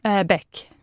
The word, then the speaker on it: Bech: This is it Niels Bech or Bech? – Bech. – Bech. Great, even yeah. Bech. Yeah. Well, it's spelled B-E-C-H.